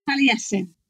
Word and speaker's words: Taliesin: So one of the books I came across when looking around this topic is a new translation of the Book of Taliesin. Have I said that right? – Taliesin.